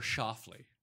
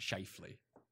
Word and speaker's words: Schauffele: It's like it's Xander Schauffele, not Shifley.